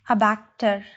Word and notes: abactor: GenAm; /ˈæˌbæk.tɚ/; /æˈbæk.tɚ/

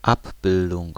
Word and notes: représentation: France; [ʁə.pʁe.zɑ̃.ta.sjɔ̃]

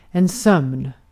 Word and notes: sleep: /sliːp/; [sliːp]

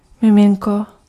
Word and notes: bambino: [bamˈbino]

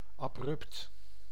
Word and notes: soudain: France; [su.dɛ̃]

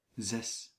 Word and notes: zes: /zɛs/

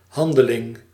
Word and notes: handeling: /ɦɑn.də.lɪŋ/

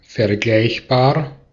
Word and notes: similar: /ˈsɪmələ/; /ˈsɪməlɚ/

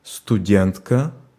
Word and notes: étudiant: /e.ty.djɑ̃/; France

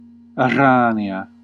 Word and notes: spider: American English; [ˈspaɪ.dɚ]

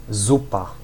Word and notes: soup: /ˈsuːp/

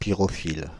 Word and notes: pyrophile: /pi.ʁɔ.fil/; France (Île-de-France)

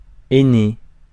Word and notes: aîné: /e.ne/; France

